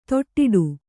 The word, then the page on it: ದಡ್ಡೆ: kannada